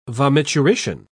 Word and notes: vomiturition: British English

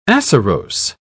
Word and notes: acerose: British English

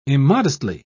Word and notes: immodestly: British English

